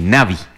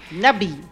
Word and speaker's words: Navi: Es de Sudáfrica, la conocen así, Navi, como Navi. – Navi.